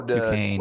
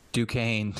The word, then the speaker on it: Duquesne: What's that second team? I can't pronounce it. – Oh, Duquesne. Quincy. – Duquesne. – Duquesne? Okay. Duquesne.